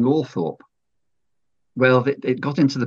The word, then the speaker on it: Althorp: I wrote to the new Earl, Charles Spencer, and got a letter back saying, yes, Althorp was definitely the pronunciation used by the family.